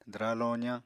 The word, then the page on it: dwraa-lozañ: apatani